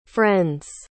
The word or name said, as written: friends